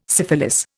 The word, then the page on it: syphilis: Indian